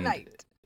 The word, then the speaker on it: knight: Because whenever there's a K and an N, you just, like, the K is silent in the English language. – Knife. – Right, knife. And knight. Exactly, yeah.